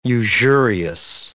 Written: usurious

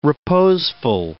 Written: reposeful

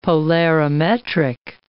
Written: polarimetric

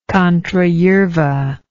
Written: contrayerva

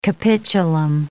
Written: capitulum

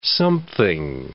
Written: something

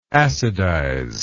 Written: acidize

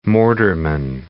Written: mortarman

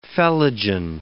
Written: phellogen

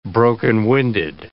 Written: broken-winded

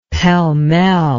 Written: pell-mell

